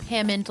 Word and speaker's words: Hammond: Hammond, like ham and eggs.